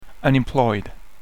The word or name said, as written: unemployed